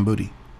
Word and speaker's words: Boutte: Have you seen the story of Kayshawn Boutte? I believe is how you say his name. – It's Kayshawn Booty.